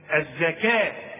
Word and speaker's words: الزكاة: الزكاه بتكتب بالواو دائما في القرآن وتنطق بالألف، نحول الواو إلى إيه؟ ألف. الزكاة، خلاص.